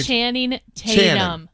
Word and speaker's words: Channing Tatum: Channing Tatum. – Channing Tatum. – It's like Channing, it's a tongue twister for him. – Channing. Why is Channing even a name? – Well, it sounds like chanting.